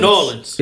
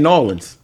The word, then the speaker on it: New Orleans: Is it New Orleans? – Nolans. – It's not. They say Nolans.